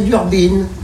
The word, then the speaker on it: Durbin: Non, on dit pas Diana Durbin, on dit Diana Durbin. Mais il y a écrit Durbin. On doit prononcer Durbin.